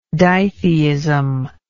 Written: ditheism

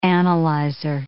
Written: analyzer

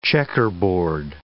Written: checkerboard

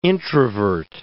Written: introvert

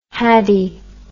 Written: haddie

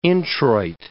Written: introit

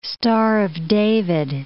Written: Star of David

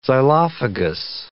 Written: xylophagous

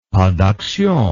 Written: pas d action

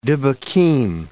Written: dybbukim

